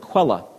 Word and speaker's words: Quelle: And I've always said the word Quelle, but she says, and maybe there's some German folks here, the correct pronunciation is more Quelle. Quelle. I hope I'm saying that right.